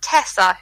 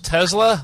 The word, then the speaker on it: Tessa: And in a game that's just about to start, I have Tessa, who is a gnome cleric. – You say her name was Tesla, like the car? – No, Tessa.